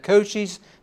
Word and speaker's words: Cochise: Cochise Stronghold is what it's called.